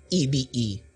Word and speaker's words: Ebe: Pero pag Pilipinas, it's really EBE. It shouldn't be that hard to pronounce. So, sabi ng kaibigan ko, the next time you explain your name, Ebe, E as in elephant, B as in ube.